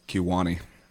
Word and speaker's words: Kewaunee: Kewaunee.